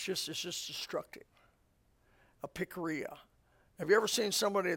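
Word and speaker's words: pikria (πικρία): The word bitterness, in the Greek, this is the pronunciation of the Greek word, pikria. Pikria. Picky. We get our picky. That's the definition of bitterness. Pikria.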